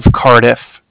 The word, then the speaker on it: Cardiff: I'm calling with the Bank of Cardiff. – Bank of Cardiff? – Yes, it's like car and then different, so it's spelled C-A-R-D-I-F-F as in Frank Frank.